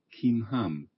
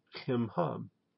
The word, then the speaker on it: Chimham: Let's look at the lemma and then pronounce. – Chimham. – Okay. Chimham.